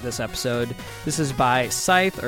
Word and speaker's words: Scythe: Will, how would you pronounce this artist's name? – Scythe. – Scythe is kind of what I was thinking.